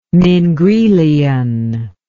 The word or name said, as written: mingrelian